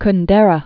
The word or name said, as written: Kundera